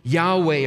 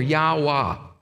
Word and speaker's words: Yahweh: And so it's pronounced often Yahweh or Yahwah.